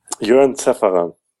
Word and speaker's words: Jörn Zaefferer: Jörn Zaefferer.